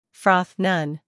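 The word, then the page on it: froth: uk frɒθ; en-us frɔːθ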